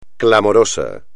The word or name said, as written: clamoroso